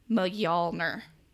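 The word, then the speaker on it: Mjölnir: But I'm looking at the pr- the pronunciation here, and I believe it's uh M- Mjolnir. Near. – Near. Mjolnir.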